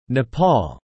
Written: Nepal

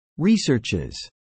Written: researches

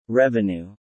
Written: revenue

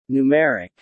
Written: numeric

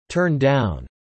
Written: turn down

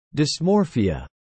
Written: dysmorphia

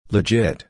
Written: legit

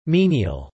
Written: menial

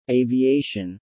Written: Aviation

